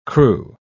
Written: crew

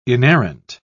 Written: inerrant

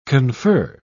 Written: confer

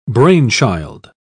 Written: brainchild

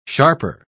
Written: sharper

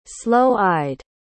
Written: sloe-eyed

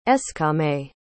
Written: eskame

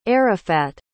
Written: Arafat

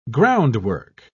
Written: groundwork

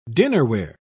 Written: dinnerware

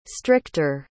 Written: stricter

